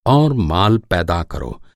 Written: पैदा

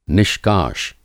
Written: निष्काश

